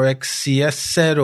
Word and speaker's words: XCSSET: So let's start with this new Mac malware, which is called XSET or XCSSet or XSET. Yeah, I pronounce it XCS set. Um, it's spelled XCSSET in all capital letters.